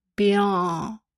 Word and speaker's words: Béarn: Béarn heißt es, aber es ist natürlich falsch ausgesprochen. Also B-E-A-R-N. – Mhm. – Mit der Betonung auf dem E. Béarn.